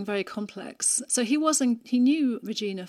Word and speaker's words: Regina: Yeah, I think a Danish person would say Regina, but Regina is fine. – I'm sorry. – It's more natural for an English person to say Regina.